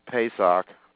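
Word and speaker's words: Pesach (פסח): This really, Pesach, or that's how you say it in Hebrew, or Passover, begins the great plan of God to redeem and purify all of mankind.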